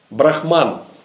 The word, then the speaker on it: Брахма: А есть брахма. Тоже ударение на первом слоге, пишется с большой буквы, но без буквы «н» на конце.